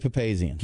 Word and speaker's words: Papazian: After 37 years, Charlie Papazian, I hope I pronounced that correct. – I believe it's correct. I believe it's Charlie Papazian.